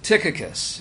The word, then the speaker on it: Tychicus: Now, I almost always pronounce the first name as Tychicus because it's what you do as an American. The Greek is Tychicus.